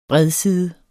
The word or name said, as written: bredside